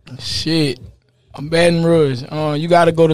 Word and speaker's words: Baton Rouge: Is that say right? Baton Rouge? How do you say Baton Rouge. – Baton Rouge. Rouge. All right, yep.